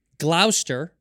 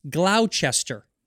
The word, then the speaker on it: Gloucester: It's Gloucester, right? Is how you pronounce it. Gloucester Road, mm-hmm. – But it's spelled Gloucester. – Oh yeah, okay. – Gloucester. But they would go Gloucester, but I'm like, no, it's Gloucester.